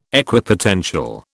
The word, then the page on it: Equipotential: British English